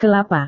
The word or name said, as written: kelapa